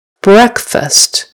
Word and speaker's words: breakfast: That's how we spell the word break, B-R-E-A-K. Careful with that vase. I don't want you to break it. However, when we put it with the word fast and make it the first morning meal of the day, it's breakfast. Breck. Breck rhymes with trek. I can't think of another one. But short E.